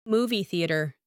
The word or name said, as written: movie theatre